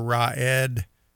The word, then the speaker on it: Raed: And, you know, we need to pray for Raed. His name is pronounced like saying raw egg, but it's Ed with a D.